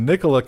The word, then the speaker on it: Nikola: Whose first name was Nikola. Is it Nikola or Nikolai? Never can figure out how to properly pronounce. I think it's Nikola in his case.